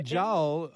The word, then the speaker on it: Jull: But Laura Joel probably could give you a pretty quick answer as well in the Department of Horticulture. Her last name is spelled J-U-L-L, just like it sounds, if I'm not mistaken.